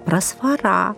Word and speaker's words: просфора: Обратите внимание на произношение этого слова. Правильно, именно так – просфора.